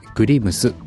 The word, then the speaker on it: GREMZ: グリムスと言います。 GREMZ と書いてグリムスというブログパーツです。